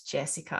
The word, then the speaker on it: Jessica: So my first legal name is Jessica.